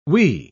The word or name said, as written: wee